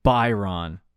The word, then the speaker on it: Byron: Is it Byron or Brian? – Byron. – It's Byron. – Yeah, it's Byron. Okay.